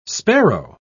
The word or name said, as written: sparrow